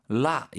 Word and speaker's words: 落: 라, 이때 발음 주의하십시오.